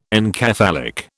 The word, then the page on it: encephalic: British English